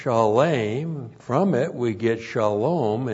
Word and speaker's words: shalom: S-H-A-L, long O-M, shalom.